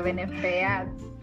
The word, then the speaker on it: Benefeats: Este, bueno, mi Instagram es Benefits, se escribe B-E-N-E-F-E-A-T-S, o sea, Benefits.